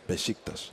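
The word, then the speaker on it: Beşiktaş: How do we say it again? – Besiktas. – Besiktas. Now, we got to get that right from here on in, because everybody gets it wrong on this side.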